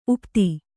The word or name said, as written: ಉಪಾಧಿ